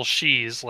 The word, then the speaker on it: sidhe: Yeah, I didn't know how to pronounce it because it's like S I D. Yeah, S I D H E. It's it's just she.